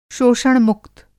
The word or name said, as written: शोषण-मुक्त